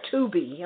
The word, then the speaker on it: Tubi: T-U-B-I. Is it Tubi or Tubi? Am I pronouncing it? – I think it's Tubi.